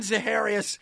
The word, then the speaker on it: Zaharias: The Babe Didrikson Zaharias of comedy.